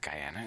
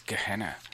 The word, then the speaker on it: Gehenna: We played a song called Hot Rod to, how do you pronounce that? Guyana? It's not Guyana, it's Gehenna. Anyway, G-E-H-E-N-N-A, Hot Rod to Gehenna, from the brand new EP Tabula Rasa.